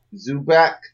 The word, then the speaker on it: Zubac: And then I have Zubac.